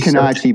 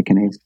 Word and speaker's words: Canace: Canace. – I didn't know if it was Italian. It was like Canace. – It is Canace, but it's changed over the years to Canace.